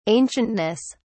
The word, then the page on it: ancientness: British English